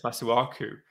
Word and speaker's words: Masuaku: It was pissing me off so much last night that McManaman kept on calling Masuaka. He was one syllable off calling him a bloody maraca, and the COCOM kept on saying, well, just he kept on saying Masuaku, trying to press the point that it's Masuaku, and then McManaman would just come in three times in a row.